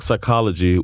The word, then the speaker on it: psychology: Dog psychology with a silent P.